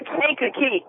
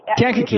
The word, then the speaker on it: Kankakee: Am I saying that right? – You know, it's Kankakee. – Kankakee.